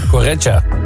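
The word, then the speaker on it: Correia: Werd de ploeg uitgeschakeld door AZ, werd 2-1 en was heel erg zuur, vindt Telstar-trainer Correa. Zeg ik dat goed? – Ik weet het niet. Heet hij zo Correa? – Extra pijnlijk. – Correa. – Correa. Nou, het is extra pijnlijk omdat ze natuurlijk 2 eigen doelpunten maakten.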